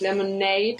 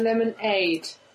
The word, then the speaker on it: Lemonade: Mit Betonung auf Aid. Nicht Lemonade, sondern Lemonade. Wie Hilfe.